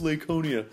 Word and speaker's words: Laconia: I probably said that wrong, so please don't tweet him and go, it's pronounced Laconia.